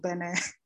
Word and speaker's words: Benin: Because so there's a colleague of mine in, from Benin, but it's pronounced Benin over here.